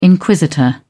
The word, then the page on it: inquisitor: British English